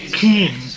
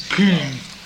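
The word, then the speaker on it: Küng: Kuhn. – Kuhn.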